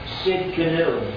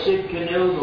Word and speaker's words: Tsidkenu: Jehovah Tsidkenu, the silent T there in front. Jehovah Tsidkenu, the Lord our righteousness.